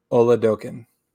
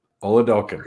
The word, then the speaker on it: Oladokun: His name, for everybody, I asked him personally because I didn't want to butcher his name. It's Oladokun. – Oladokun.